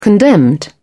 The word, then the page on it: condemned: British English